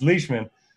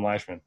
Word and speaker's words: Leishman: So I pronounced that wrong so many times. I went on a couple podcasts, and that's what I was known for, was pronouncing his name. it's Leishman. I used to call him Leishman.